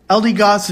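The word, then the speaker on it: Eldegoss: Rebel Clash is out, so we're going to talk about LD Goss V.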